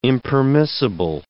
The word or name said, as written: impermissible